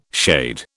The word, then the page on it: shade: British English